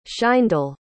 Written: shayndel

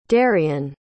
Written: daryan

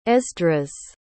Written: esdras

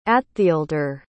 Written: attheaeldre

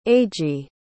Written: eiji

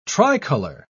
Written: tricolor

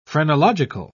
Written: phrenological

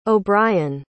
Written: obrien

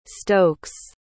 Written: stokes

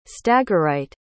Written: stagirite

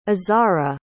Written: azhara

